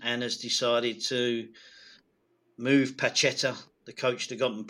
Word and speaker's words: Pacheta: A fella called Juan Jose Rojo Martin, better known as Pacheta, I think is the right pronunciation.